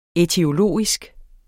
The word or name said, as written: ætiologisk